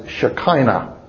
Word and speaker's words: Shekinah: It's commonly pronounced in English as Shekinah.